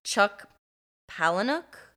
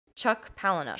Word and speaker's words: Chuck Palahniuk: Chuck Palahniuk books. – I think that's exactly like how you say it. – Fight Club and stuff. – Yeah. – Okay. Chuck Palahniuk. – Chuck Palahniuk.